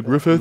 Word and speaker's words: Griffith: Ja, Griffith ist halt mit TH am Ende, Griffith.